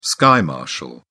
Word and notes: sky marshal: British English

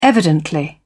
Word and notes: evidently: British English

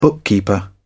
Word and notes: bookkeeper: British English

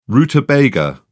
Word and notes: rutabaga: British English

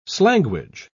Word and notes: slanguage: British English

